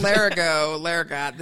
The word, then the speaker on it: Larigot: Larigot. The T is silent, I believe.